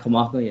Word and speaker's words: Kamaka: Yeah, Kamaka, yeah.